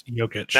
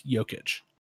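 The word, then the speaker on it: Jokić: And Nikola Jokic is going to be in there because it's Jokic. – You know what? Why not? Because Nikola Jokic is loved Jokic by freaking everybody. – It's a soft J. It's Jokic.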